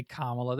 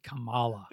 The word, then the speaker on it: Kamala: They didn't say Kamala. They said Kamala.